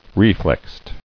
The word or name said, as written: Reflexed